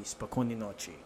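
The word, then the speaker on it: spokojnoy nochi (спокойной ночи): So the word for good night in Russian is, if you tried to say it right, it would be spokojnoy nochi.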